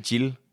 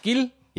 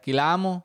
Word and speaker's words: Guillermo: Nej, ikke Jill. – Jill. – Nej. – Gil. – Ja. – Guillermo.